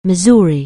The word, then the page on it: Missouri: anglais